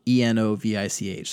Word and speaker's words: Mrdjenovich: So Ashland County Sheriff's Deputy Brock, I don't even know how to pronounce this guy's last name, Mrdenovich. So it's Mrdjenovich. So there's like no vowel for the first four consonants.